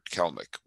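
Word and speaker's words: Kelenic: They've been floating around, but they have decided by a miracle to start calling up some of their best players, including Jared Kelnick, which, by the way, is how you evidently pronounce his name.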